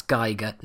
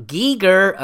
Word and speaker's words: Giger: Yes, for our American listeners, that's Geiger, not Giger, okay? Just one of the many words you guys don't fucking pronounce properly, but there you go.